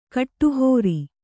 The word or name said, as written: ಕಡೆಹರಿ